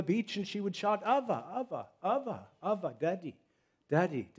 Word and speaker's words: Abba: And we have the privilege of addressing God our Father by the ancient Aramaic term of Abba, or more correctly, Ava. They pronounce it as a soft B. It's almost a V. Ava.